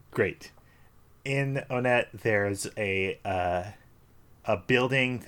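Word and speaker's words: Onett: We pronounce it Onet, right? – Ryan, you're going to be mad. – No way! – I say Onet! – Oh, okay. At least you don't. – No, I don't. I don't do the cursed pronunciation. – Okay. – Good. I do not do the cursed pronunciation. I say on it.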